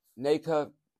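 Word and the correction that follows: Nacua: Diese drei, also Duran und Naker, ja, wie wird der ausgesprochen? Nacur, der Wide Receiver, Puka Nacur und Duran sind noch auf Questionable.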